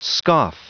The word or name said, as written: scoff